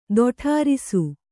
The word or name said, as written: ತೊಡರಿಸು